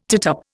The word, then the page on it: tittup: Indian